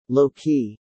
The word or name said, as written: Low-key